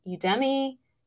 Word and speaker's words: Udemy: But I encourage you to look at what's free and available online, such as, I always pronounce this wrong, but it's Udemy. Okay, yeah. Am I pronouncing it correct? – I think that's right.